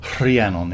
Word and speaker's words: Rhiannon: It's awkward because it's Rhiannon. It's kind Rhiannon. Of so because there's an H in there, yeah, and a sort of rolled R.